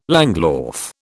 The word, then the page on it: langlauf: British English